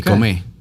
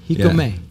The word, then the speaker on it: Jicome: How do you spell that? – J-I-C-O-M-E with an accent. Okay. Hikome. – Hikome.